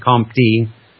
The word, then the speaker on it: Comte: But although Henry St. Simon coined the term industrial and introduced the notions of positive and philosophie positive before Auguste Comte, it was Comte, C-O-M-T-E, I'm just going to say Comte, it's pronounced Comte, like you're just swallowing your tongue.